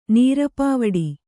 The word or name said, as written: ನೇರ್ಪುವಡೆ